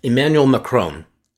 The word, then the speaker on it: Emmanuel Macron: and I want to make sure I say his name correctly because I messed it up the other day. It's Emmanuel Macron.